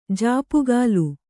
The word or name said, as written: ಝಾಪುಗಾಲು